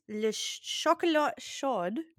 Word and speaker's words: le chocolat chaud: So Parisian hot chocolate, pronounced in French as le chocolat chaud. – Hot chocolate. – Yeah, it's literally hot chocolate, but in French. It's the French way to pronounce it.